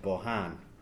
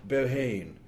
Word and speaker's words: Bohane: First of all, I'm going to read a little bit from City of Bohan, and wonderful English publishers, Jonathan Cape, keep calling it Bohane, the wonderful city of Bohane. So I've been correcting them for the last year about the pronunciation, about getting it right, the Han bit.